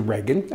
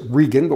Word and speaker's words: Reagan: we'll pronounce it Regan, but we'll have the same spelling, hey, and we got a Regan, pretty cool stuff.